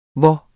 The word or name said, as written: hvor